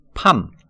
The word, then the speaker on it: palm: palm, p-a lm 여기서 중간에 l은 묵음입니다. 팜, 야자수라는 뜻도 있고 손바닥이라는 뜻도 있습니다.